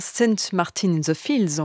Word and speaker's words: St. Martin in the Fields: L'Académie de Saint-Martin, St. Martin in the Fields, on va le prononcer à l'anglaise, dirigée par Sir Neville Mariner, dans ce pétillant rigodon tiré de la suite du temps de Holberg d'Edvard Grieg.